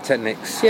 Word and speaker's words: Technics: How do you say the company? – Technics.